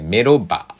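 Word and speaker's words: MELOBAR: の、にある、えー、メロバ、 M-E-L-O-B-A-R で、メロバの、えー、メロバーに昨日ね、行ってきました。えー、ニャムさんがね、えっ、ー、と、まあ、あの、えー、まあメ、ロバー行と時ね、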